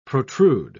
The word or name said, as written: protrude